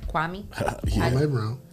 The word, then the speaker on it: Kwame Brown: Kwame Brown. – Is that how you pronounce it? – Yeah, Kwame or Kwame.